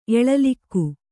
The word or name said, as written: ಎಲೆಲೆಗ